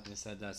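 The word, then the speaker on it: Misada (מסעדה): Misada.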